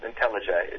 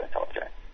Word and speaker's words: IntelliJ: The two development environments that I know people use for Java are Eclipse and this thing called, I think it's pronounced IntelliJ. It's just spelled IntelliJ with a capital J at the end.